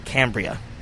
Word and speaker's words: Cambria: Cambria.